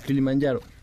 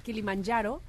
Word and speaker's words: Kilimanjaro: Kilimanjaro, ¿lo dije bien? – El Kilimanjaro. – Kilimanjaro y este logro que lo hizo en 2017, cuando él apenas tenía tan solo 13 años, qué bárbaro.